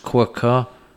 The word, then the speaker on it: Quooker: This podcast is sponsored by Quooker, pronounced Quooker.